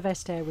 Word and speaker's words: Vestager: The vast majority of people, we believe, firmly believe, pronounce her name wrong, and we believe that Vestager is the correct, or at least an Anglophile interpretation of the correct pronunciation. So I'm not going to attempt. – Commissioner Vestager was nominated, there were conference calls about how to pronounce Commissioner Vestager's name.